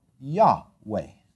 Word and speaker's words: Yahweh: And they would pronounce it now Yahweh.